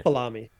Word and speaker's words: Falami: Falami. – Yeah. Rhymes with salami, which I think Mark jokes about.